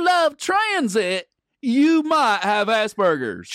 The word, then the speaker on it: Asperger's: Asperger's, it's pronounced.